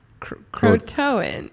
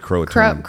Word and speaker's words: Croatoan: C-R-O-A-T-O-A-N. Crotoan. Look, you want to look that up and see how you pronounce it? I don't think I pronounced it right. – Should we do the thing where they... – where they say it for us. – Okay. How to pronounce... – C-R-O-A-T-O-A-N. Crotoan. – Crotoan. I'm going to go with Crotoan. – Crotoan. Yeah, I think you're probably right.